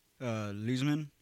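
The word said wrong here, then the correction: Losman: I feel like I'm pronouncing it wrong. – Lossman. JP Lossman.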